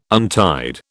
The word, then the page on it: untied: British English